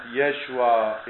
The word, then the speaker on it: Yeshua: Even the name of Jesus is pronounced Yeshua in Aramaic, it is pronounced in one way Isus in some languages, it's pronounced Jesus or Jesus.